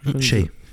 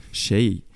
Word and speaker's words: Xay: wie spricht man, Xay? – Shay. – Shay.